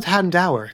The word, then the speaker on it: Houndour: I've always said Houndour, because, like, dour.